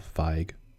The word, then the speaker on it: Feig: Paul Feen? – Paul Feig.